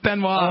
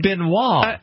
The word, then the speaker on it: Benoit: B-E-N... – Benoit. It's not Benoit. – O-I-T. Benoit. It's a French name. It's Benoit.